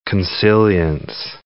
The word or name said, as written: consilience